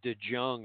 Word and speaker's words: DeJong: It's like DeJong or something. It's spelled weird, but it's pronounced DeJong.